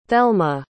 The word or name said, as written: thelma